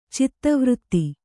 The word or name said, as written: ಚೇತೋವೃತ್ತಿ